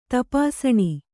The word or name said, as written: ತಪಾಸಣಿ